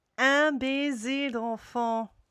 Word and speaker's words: Un baiser d'enfant: So it's super interesting to think like, okay, this song, because like the infant kiss, okay, how do you say it again? – Un baiser d'enfant. – Un baiser d'enfant. – It's close enough.